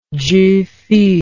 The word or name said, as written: zhifu